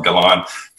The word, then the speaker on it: Galon: There's Joey Galon. My last name is spelled Galon. Think beauty salon and replace the S with the G.